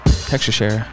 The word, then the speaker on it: Teixeira: Texashera.